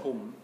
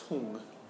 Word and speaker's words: Hum: Yeah, so it's not hum, or hum, as I've heard sometimes chanted. No, it's hung. If you write it phonetically, it's H-O-O-N-G, hung. Though it's spelt in English, H-U-M.